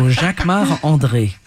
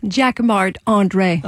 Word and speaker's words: Jacquemart-André: You say it like a Frenchman. – I'm French. So Jacquemart-André. – And say it so we can understand it. – Jacquemart-André.